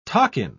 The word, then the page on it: takin: British English